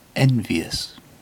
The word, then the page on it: envious: British English